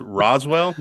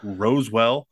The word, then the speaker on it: Roswell: It's Roswell, not Rosewell.